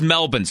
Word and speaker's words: Melbourne: This the way to say it is Melbourne. So when you hear people saying Melbourne, there's no R in there. It's silent.